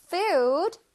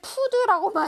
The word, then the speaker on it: food: 우리 친구들 푸드라고 많이 하죠. 푸드 아니죠. 푸드 아니고 food. 우 소리를 길게 food.